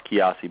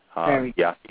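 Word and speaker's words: Nkiyasi: It's Kiasi Helm. And, you know, it's pronounced Kiasi, but it's spelled with an N, and that's N-K-I-Y-A-S-I, and the last name is H-E-L-M as in Mary. Kiasi Helm.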